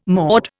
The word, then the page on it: maud: Indian